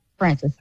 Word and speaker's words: Francis: Did I pronounce that correctly, Francis? – Francis, that is correct.